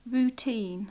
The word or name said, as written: routine